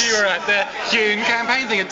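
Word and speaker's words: Huhne: Chris Hune. How do you pronounce it again? – Hune. – Just the Hune. – Definitively. – Hune makes you breathe more easily. – Definitively, the definitive pronunciation.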